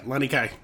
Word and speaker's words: Lanikai: Yeah, Lanikai.